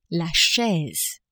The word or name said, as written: chaise